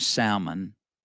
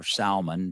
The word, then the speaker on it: Salmon: And Rahab ended up marrying a man from the tribe of Judah named Salmon. Or Salmon, depending on how you want to say it. He wasn't the fish, but it's spelt like the fish.